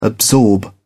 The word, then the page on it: absorb: British English